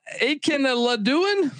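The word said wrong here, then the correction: Akinmoladun: Akamuladon. – There it is. – Akamuladon.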